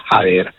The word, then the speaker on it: haber: Va a saber o non va a saber, c'è un H di differenza, la pronuncia è praticamente la stessa, perché aver, che si scrive H-A-B-E-R, la B si pronuncia V e quindi diventa aver.